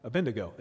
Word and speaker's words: Abednego: Shadrach, Meshach, and Abednego. Huh? Abednego.